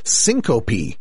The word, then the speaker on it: syncope: They say syncope is how it's pronounced.